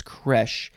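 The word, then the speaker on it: crèche: Is it creche? I think it's creche. Yes, creche or creche, spelled C-R-E-C-H-E with an accent on the first E.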